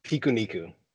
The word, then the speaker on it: Pikuniku: Pikuniku.